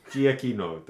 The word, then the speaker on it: Giacchino: And excuse my pronunciation, but it's by Michael Giacchino. Giacchino, there we go.